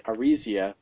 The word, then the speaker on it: Arisia: Like, then I realized that there was an I in there, so when you started pronouncing it, Arisia, I don't know, that sounds pretty good to me.